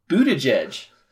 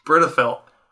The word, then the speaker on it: Buttigieg: Buttigieg. – Britifelt.